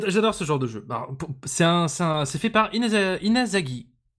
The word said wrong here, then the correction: Izanagi: Inazagi, Izanagi Games, Izanagi Games.